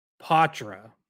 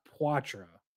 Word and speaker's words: Poitras: Although, actually, I should correct myself, because Steve Conroy asked Poitras today how you pronounce his name, and he said Poitras, not Poitras. So, like, okay. – French-ish, but not the full French.